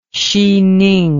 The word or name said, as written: xining